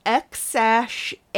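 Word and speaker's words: X Æ A-12: It's pronounced X-A-12.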